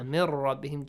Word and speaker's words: مِنْ رَبِّهِمْ: Mir rabbihim, just merge them and do not pronounce, and do not produce a nasal sound. Mir rabbihim, no. no nasal sound, just mir rabbihim. Make sure ra has a heavy sound.